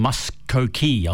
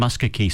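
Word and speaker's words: Muskogee: And he's filled in a bit of information for us on the second producer's choice, Oki from Muskokee. I'm sure I mispronounced that first, so it's Oki from Muskokee.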